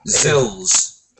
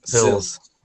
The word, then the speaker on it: XILS: Oh, yeah, but how do you say that? – Zills. – Zills. – Yeah. – XILS. – XILS.